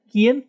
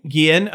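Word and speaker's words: Guillén: Guillen. – Guillen.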